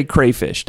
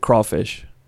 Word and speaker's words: crayfish: So Crawfish is C-R-A-Y? – Well, we pronounce it crawfish.